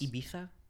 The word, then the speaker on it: Ibiza: That's specific to, like the Ibiza culture, and, like, that's how it is. – It's pronounced Ibiza, but...